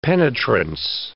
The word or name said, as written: penetrance